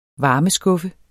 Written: varmeskuffe

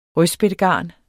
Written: rødspættegarn

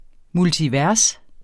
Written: multivers

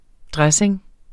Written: DRESSINGENS

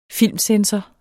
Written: filmcensor

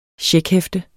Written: checkhæfte

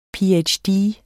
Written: ph.d.

